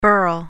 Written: birl

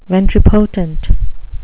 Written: ventripotent

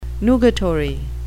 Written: nugatory

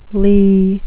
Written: lee

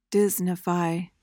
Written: Disneyfy

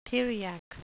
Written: theriac

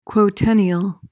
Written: quotennial

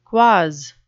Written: quoz

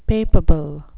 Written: papabile

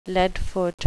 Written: leadfoot

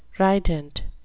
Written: rident

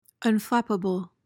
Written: unflappable

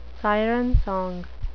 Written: siren song

